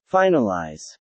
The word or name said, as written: finalize